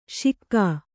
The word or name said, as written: ಶಿಕ್ಕಾ